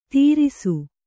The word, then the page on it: ತೀರಿಸು: Kannada